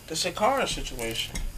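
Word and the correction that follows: Sha'Carri: Nigga, you mispronouncing the fuck out her name. – Right. – Shakari, nigga. Shakari. This nigga said Shakara.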